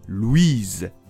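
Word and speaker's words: Louise: 例えば、先ほどの名前のランキングに男女どちらもランクインしていたルイとルイーズ。ルイが男の子の名前で、それにうがついて語尾の S を発音するルイーズが女の子の名前です。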